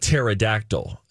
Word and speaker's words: pterodactyl: And he was doing that, and that came from a bad place because Terry the pterodactyl on Pee-wee's Playhouse is spelled P-T-E-R-I, just like pterodactyl has a silent P.